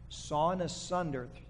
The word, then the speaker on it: sawn asunder: Hebrews chapter 12 says they were being sawn in sunder. There you go. I think I said it right. Say that time three times real fast. Sawn asunder.